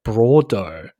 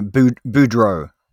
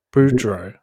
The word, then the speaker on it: Boudreaux: they keep pronouncing it Parker Bordeaux. – No, Boudreaux. – Boudreaux. – Yeah. – But it's Bordeaux, isn't it? – No. Boudreaux. Boudreaux. – Yeah, Boudreaux. Okay.